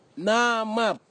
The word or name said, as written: namap